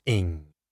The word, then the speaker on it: Ng: Chitat, pronounced Cheetah, and NG, pronounced Ing.